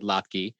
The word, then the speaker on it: latke: My British grandmother would have said latke. Most of us say latke, but that's latke. Only because it's winning out. – What did you say, latke? – Latke rhymes with vodka.